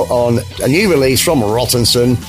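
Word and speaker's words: Rattanson: Rottenson.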